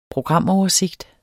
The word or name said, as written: programoversigt